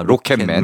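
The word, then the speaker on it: Rocket Man: 락켓맨. 락켓맨. 네. 어. 아, 발음이 좀 락켓맨 그러니까 너무 좀 굴리네요. 아, 로켓맨.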